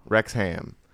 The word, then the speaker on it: Wrexham: Wrexham? – Wrexham. Which is... – Spelled with a W, though.